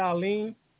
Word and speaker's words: Alim: Yes, it's Dr. Alim Bey. – A-L-I-M-Bey. – Okay, D-E-Y-O-I-M. – A-L-I-M.